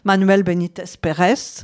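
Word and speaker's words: Manuel Benítez Pérez: El Cordobès, par exemple, alors qu'il s'appelait, je ne sais pas prononcer l'espagnol, Manuel Benitez Pérez. – C'est pas mal, c'est bien.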